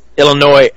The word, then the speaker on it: Illinois: Illinois. God damn it, I hate when I pronounce the S. Illinois.